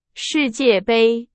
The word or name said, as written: 世界杯